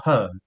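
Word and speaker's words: H.E.R.: She doesn't call herself her. – You say it like her.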